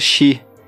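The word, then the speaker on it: Shi (四): Sem falar também que essa soma de 1 e 3, ou 4, no Japão se pronuncia Shi, que é a mesma pronúncia de morte.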